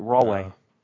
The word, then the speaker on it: Rahway: It's Rahway. – And why do you pronounce it Rahway? – That's how you pronounce it, Rahway.